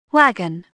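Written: wagon

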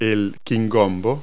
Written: okra

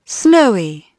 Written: snowy

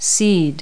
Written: seed

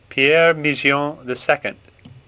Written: Migeon

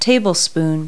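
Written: tablespoon

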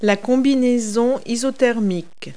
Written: wetsuit

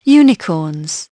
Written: unicorns

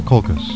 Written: COLCHIS

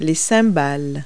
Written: cymbals

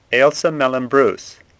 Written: Bruce

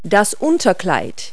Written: slip

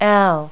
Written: l

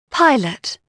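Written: pilot

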